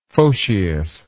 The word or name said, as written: photius